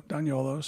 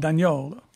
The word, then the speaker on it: D'Agnolo: D-O-T. – Yeah. And also myself, I'm on Instagram at Otto Daniolo, spelled the way my name is spelled. – You finally said it, Daniolo. – Daniolo. Yeah. D-A-G-N-O-L-O.